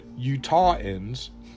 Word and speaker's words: Utahns: First, I want to tell you about Senator Mitt Romney is among 220,000 Utahans, and yes, that's how you say it, Utahans. It's spelled U-T-A-H-N-S. So Utah, it's not U-T-A-H-E-N-S, it's just N-S, which is kind of interesting in its own little thing.